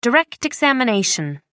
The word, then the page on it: direct examination: American English